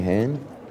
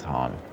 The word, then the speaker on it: Han: Anything, Han? – It's Han, but that's okay.